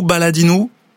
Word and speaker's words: Baladinou: Vous allez me dire si je prononce bien, ou Baladinou. – Ouais, c'est ça, c'est bien voilà.